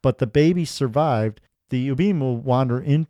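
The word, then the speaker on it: Ubume: I think it's pronounced Ubin. – Okay. All right. – Which actually is pretty good because you're not supposed to say the real name of the demons. – Mm-hmm. So we just mispronounce everything. – Yeah, we're fine. – Nothing will follow us home. – And Ubin, I think that's, it's U-B-U-M-E. So it's Ubin.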